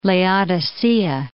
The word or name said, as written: Laodicea